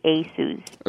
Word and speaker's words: Asus: So the correct pronunciation is actually Asus.